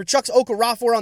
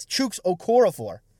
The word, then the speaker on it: Chuks Okorafor: For Chuks Okorafor on the offensive line, well, actually, I guess I'm pronouncing that wrong, right? It's Chuks Okorafor.